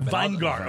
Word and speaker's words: Vingar: To our lovely little Houston studio here, and we'd love to introduce to you now Omar Vingar. Did I say it right? – Close. It's Vingar. – Vingar. Okay. – I will Vingar. Take anything other than vinegar because it looks a lot like it. – So yeah, it's a V-I-E-N-G-A-R.